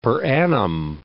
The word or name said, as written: per annum